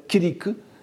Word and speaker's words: kiriku: Ici, vous en avez un autre avec ri, alors prononcé en sanskrit pas c'est-à-dire et en japonais kiriku.